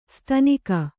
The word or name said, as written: ಸ್ಥಾನಿಕ